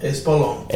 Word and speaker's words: Espolón: The Espolón. I said that right? – Espolón.